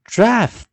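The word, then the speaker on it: draught: 所以记得啊，一般 G H T 的时候，G H 是不发音，但在 draft 的时候，G H 又念 F 了啊。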